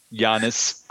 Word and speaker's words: Giannis: If you're gonna say the first name, it's Giannis. You say it properly with a G sound.